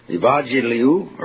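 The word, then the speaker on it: Evangeliu (εὐαγγελίου): Evangeliu, or evangeliu, the two G's in the Greek are always pronounced NG.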